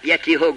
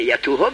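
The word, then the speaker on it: يتوه: یتیهو گفته، پیش اونا مسخره میمانه، میگن تو داری غلط تلفظ میکنی، یتوهو بگو.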